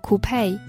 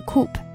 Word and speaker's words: coupe: 然后有一种比较酷炫一点的车，它叫做 coupe，或者是 coupe，这个词两个发音都可以。